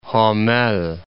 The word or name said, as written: Homel